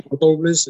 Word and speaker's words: Towles: Amor Toulles, que supongo que no se pronuncia así, será Amor Toulles. – Efectivamente, pues, es Amor Toulles, es un escritor norteamericano.